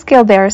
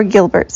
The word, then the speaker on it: Gilbert: All right, what is Gilbert syndrome? Or Gilbert syndrome, depending on how you pronounce it.